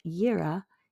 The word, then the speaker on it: Yireh: And then the Yireh is Y-I-R-E-H, and it means the Lord will provide.